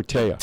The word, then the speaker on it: Taya: Carissa says Nick and Taya, am I saying that right? T-A-Y-A or Taya? – Taya Valkyrie.